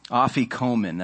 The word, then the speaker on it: afikomen: That broken bread, still done today, is placed in a afikomen. That's the name of this linen. I want credit for pronouncing it correctly.